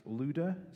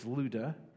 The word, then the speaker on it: Lydda: I know it looks like Lydda or Lydda, it would be pronounced Luda, so I'm going to try to pronounce that correctly as we go through, even though my mind says to pronounce it a different way. It's Luda.